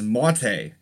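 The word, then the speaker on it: Matěj: First name is Mate. M-A-W-T-A-Y is a pronunciation. It's spelled M-A-T-E-J.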